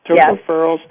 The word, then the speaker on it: tocopherols: Tocopherols.